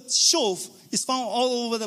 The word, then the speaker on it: shuv: In fact, it says, return, restore, is the same word, shuv, S-H-U-V. It's pronounced shuv.